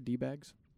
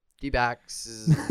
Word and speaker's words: D-backs: D-bags? – D-backs.